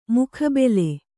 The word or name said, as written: ಮುಖಾಬಿಲೆ